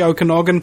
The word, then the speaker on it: Okanagan: Is that how you pronounce it, Okanagan? – Yes. – Okay. Because it occurs to me, interestingly enough, that if you're trying to mess with the Okanagan people, you can't be right in your noggin.